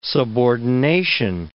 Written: subordination

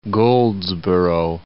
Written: Goldsboro

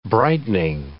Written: brightening

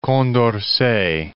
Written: Condorcet

